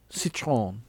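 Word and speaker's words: Citroën: How do you say that? Citroen? – Citroen. – Yes.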